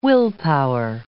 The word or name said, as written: willpower